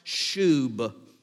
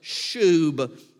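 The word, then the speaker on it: shub (שוב): The one that is here is the Hebrew word pronounced shub. It rhymes with the word tube, but it's a shub.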